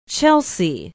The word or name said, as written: chelsea